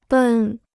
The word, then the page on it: 奔: bèn